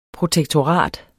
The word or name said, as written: protektorat